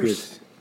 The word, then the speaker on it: Pierce: Paul Pierce, tá correto a pronúncia? Paul, Paul Pierce. Pierce.